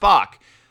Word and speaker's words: Back: Everybody knows my name is Back because it's spelled Back, but it's pronounced Bach. But I called in, made my reservation. What's your name? Daniel Bach.